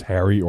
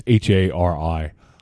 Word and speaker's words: Hari: I don't know if it's pronounced Harry or Hari. It's Hari.